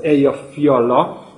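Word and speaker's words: Eyjafjalla: És akkor egy-két mondatot az Eyja Fialla nevű vulkánról. Kacifántos ugye a nevét megjegyezni, de ha izlandiak lennénk, akkor még bonyolultabban kéne kiejteni, mert Eyja Fiallának ejtik, tehát a két elt az T betű hangzással.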